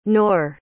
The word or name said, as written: noor